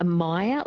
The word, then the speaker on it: Meyer: So maybe a Meyer lemon, so M-E-Y-E-R. – That's it, yes. Excuse my bad pronunciation of it. – Oh, good. It's it, yeah, Meyer lemon.